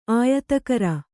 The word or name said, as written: ಆಯತಕರ